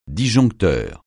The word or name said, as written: disjoncteur